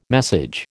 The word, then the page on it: message: American English